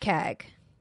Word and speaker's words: CAG: And so the commander of the air group, that's pronounced CAG, is sent out to do recon.